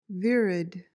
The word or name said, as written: virid